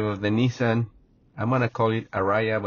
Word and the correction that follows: Ariya: It's the Nissan Ariya.